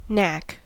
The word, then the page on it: knack: American English